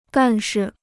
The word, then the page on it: 干事: gàn shi